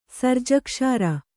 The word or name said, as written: ಸರ್ಜಕ್ಷಾರ